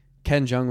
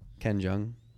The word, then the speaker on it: Ken Jeong: Ken Jeong. – Oh, Community has a lot. – Am I saying that right? Jung? Ken Jung? – Kim Jung. – Yeah, okay.